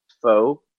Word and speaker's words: faux: Charlotte Flair with Becky, Bayley, and Sasha are the four Fox women, all right? – You're also saying that wrong. It's faux. It's French.